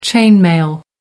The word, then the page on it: chain mail: American English